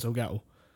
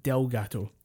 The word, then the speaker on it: Elgato: Like he's Elgato captures wrong, and he always mispronounces Elgato. I think he calls it Delgato.